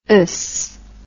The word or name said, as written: ous